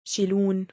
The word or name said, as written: شْلونَک؟